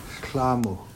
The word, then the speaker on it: shlamu: We're recording in Deming, Washington, and we want to give a word of the month, and we want to go with the word rain, and it's pronounced shlamu.